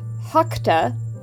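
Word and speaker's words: Rakta: After that, a strange little goth psychedelic Brazilian post-punk group called Hakta, which is spelled with an R at the beginning instead of an H, but because it is Portuguese, the R is pronounced like H.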